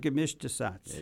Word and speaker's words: Gemischter Satz: The Rudi Biber Gemischtesatz.